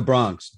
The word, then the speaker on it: Bronx: I was on a call this morning and a native of the Bronx said, no, it's not the Bronx. He said, it's the Bronx, D-A, then B-R-O-N-X, for those of us old enough to remember the pronunciation.